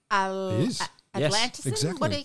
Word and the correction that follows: Atlassian: Atlassian. – Atlassian.